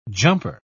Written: jumper

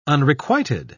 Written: unrequited

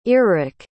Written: earric

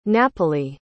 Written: napoli

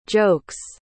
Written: jokes

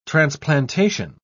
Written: transplantation

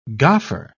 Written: goffer

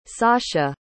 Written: sascha

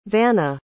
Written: vana

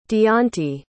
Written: deonte

